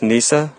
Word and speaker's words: Kneesaa: And I put the dang Princess Kanisa on the new intro just for you. Now, do you understand that I love Ewoks and I accept them? Um, it's pronounced Kneesaa, but um, no, I do understand.